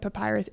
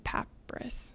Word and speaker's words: papyrus: Like, I think I said papyrus. It's papyrus. I said that wrong.